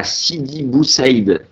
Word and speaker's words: Sidi Bou Saïd: Alors Selima, t'es née le 8 juillet 77 à Sidi Bou Saïd.